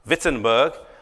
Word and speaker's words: Wittenberg: This is the town called Wittenberg, or I'm not sure if you're supposed to pronounce it with a V as Wittenberg. I'm, you are. Wittenberg.